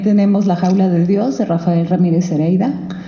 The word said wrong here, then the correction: Heredia: Heredia, lo dije mal, y La Vida Mental, de Henry Wallum.